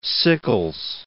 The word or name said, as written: Sickles